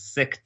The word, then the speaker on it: Sikh: Sikh temple, or actually what's, I think the correct pronunciation is Sikh temple.